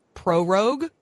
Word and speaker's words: prorogue: What does it mean to prorogue? Am I saying that right? Parliament. – That's right.